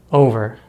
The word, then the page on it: over: American English